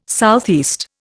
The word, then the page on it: SE: Indian